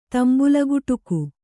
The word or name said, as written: ತಂಬುಲಗುಟುಕು